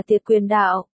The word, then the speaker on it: tiệt quyền đạo: Tên của môn võ này chữ Hán là chia quan giao, nếu dịch theo đúng nghĩa Hán Việt thì phải đọc là tiệt quyền đạo.